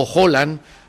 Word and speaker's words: Haaland: a un país trascendental que tuvo a Haaland, o Holland, como dicen ellos que se pronuncia, como su Correcto.